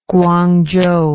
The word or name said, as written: Guangzhou